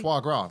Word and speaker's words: foie gras: Foie gras.